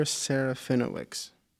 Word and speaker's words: Serafinowicz: I think it's supposed to be pronounced Peter Serafinowicz.